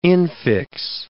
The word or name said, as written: infix